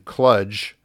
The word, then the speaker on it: kludge: Tobias, he weighs in favor of the correct pronunciation, kludge, and he says it has to be kludge as in fudge.